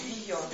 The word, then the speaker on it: Вийон: Просто чтобы вам было понятно, и мы больше к этому не возвращались, существует две традиции записи иностранных имен. Подчиняясь фонетике, вот как раз Вийон, и подчиняясь тому, как написано на бумаге.